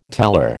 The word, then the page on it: teller: American English